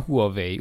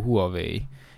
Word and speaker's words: Huawei: Hát persze, hát ott van a Huawei. Úgy, hogy ejtjük ki magyarul Huawei, és közben Huawei. Huawei.